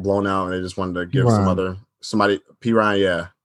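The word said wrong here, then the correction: Perine: What did I say? – Perrine. – P. Ryan, yeah. I just pronounced it wrong.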